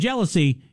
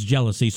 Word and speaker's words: jealousy: And then you have lousy, which we pronounce by itself, lousy. But in jealousy is jealousy.